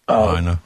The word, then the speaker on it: Lahaina: As was his deputy chief, Hank, and both of them now are leading this investigation, or are in, how do you pronounce that, Lahaina? – Lahaina.